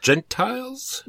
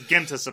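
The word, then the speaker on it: Gentes: There's no L, so it's not Gentiles. That's not what it's about. – Genties? – Well, I mean, I suppose it is about Gentiles, but it's pronounced Gentis, apparently. That's the Latin. – Gentis. – That's the Latinist pronunciation.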